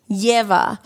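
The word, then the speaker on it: Ieva: Yeva. – So I constantly want to say Leva because I always see the I for an E. So it's I-E-V-A, Yeva.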